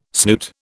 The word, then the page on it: snoot: British English